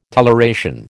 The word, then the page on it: toleration: American English